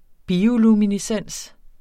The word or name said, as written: bioluminescens